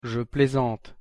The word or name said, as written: je plaisante